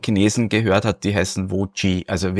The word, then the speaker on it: Voge: Die Händler sagen, mir heißt Voge.